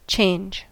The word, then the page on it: change: American English